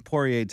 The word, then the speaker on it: Poirier: No, but Poirier, how do you pronounce Poirier? – Poirier.